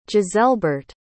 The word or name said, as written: giselbert